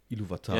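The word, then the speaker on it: Ilúvatar: Ich meinte Iluvatar.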